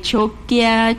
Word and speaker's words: xoquia: Xoquia, y cuando una palabra empezaba con la X, ahora es cho. Agarra la pronunciación de la che, ¿verdad? Entonces, xoquia, choquia, choquilla, como tú lo pronuncies, sí tiene esa traducción del náhuatl, hedor o mal olor.